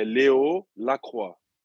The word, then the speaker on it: Leo Lacroix: Então, a pronúncia é, é Léo é Leo Lacroix.